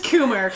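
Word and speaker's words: Kummer: Are you sure it's pronounced? Wait, it's really Chris Comer? – Maybe it's Coomer. It's K-U-M-M-E-R. – So it's probably Coomer. Okay. Chris Coomer.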